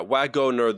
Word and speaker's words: Wagoner: Three syllables? Is there a second E in there? – Wagoner? – Yeah, Wagoner. There's W-A-G-O-N-E-R. – Oh, it's a wagon. – Someone who drives a wagon.